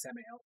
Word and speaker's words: Samael: Because it looks like it should be Samuel, but it's Samael. Samael. – It's Samael.